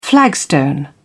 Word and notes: flagstone: British English

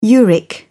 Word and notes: uric: British English

